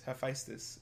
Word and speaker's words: Hephaestus: Hephaestus? – Hephaestus? Hephaestus, yeah.